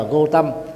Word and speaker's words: Gautama: Còn tiếng Pali và Sanskrit đọc đơn giản là Gautam, vì chữ A đứng ở cuối từ thì không đọc. Gautama thì đọc là Gautam.